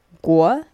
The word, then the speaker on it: 国: «го» или готя это страна. «Го» произносится вторым тоном – а вот «во» третьим.